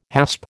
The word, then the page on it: hasp: American English